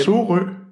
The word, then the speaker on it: Sorø: Sorø. Jeg kan ikke udtale det. – Prøv igen. Sorø. Ja.